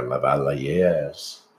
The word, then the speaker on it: lavalier: So we can use our lavaliers. – Lavaliers. For sure. – Yes. I'm so excited to say lavalier. I wonder if I'm saying it properly. – That sounds good.